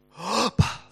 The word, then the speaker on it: Abba: And because you are sons, God has sent the Spirit of His Son into your hearts, crying, Abba, Father. That's how you pronounce that word.